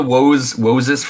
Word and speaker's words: Woses: Woses. I kept reading that as Moses, like pronouncing it as Moses, but with a W. – Woses. Yeah.